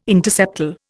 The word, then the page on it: interseptal: Indian